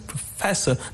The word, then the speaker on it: professor: Even like the word professor, the stress is on the second syllable, professor, not professor, no, but professor.